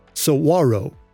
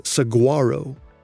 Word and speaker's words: saguaro: A friendly listener from Arizona contacted me and told me that people in Arizona say saguaro, rather than how I said it, which was saguaro. The word is spelled S-A-G-U-A-R-O. I've heard it said both ways, but yeah, saguaro is the best way to say it. That's the correct way.